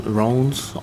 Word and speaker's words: Rennes: Rennes, R-E-N-N-E-S, is that them? – That's it. – Wrong, or Rennes, that's different. – Sounds like a disparate. – Yeah. – Don't pronounce in the French name. Rennes.